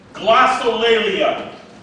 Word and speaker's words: glossolalia: Okay, secondly, the technical term for this gift in Greek is glossolalia. Glossolalia. It's spelled just like it sounds. G-L-O-S-S-O-L-A-L-A-O-L-A-L-I-A.